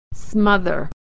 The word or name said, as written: smother